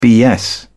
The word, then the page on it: bs: British English